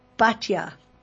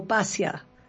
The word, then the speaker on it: Batya: but her name was Batya or Basya, whichever way you'd like to pronounce it, Ashkenazi or Sephardi or Ashkenazi.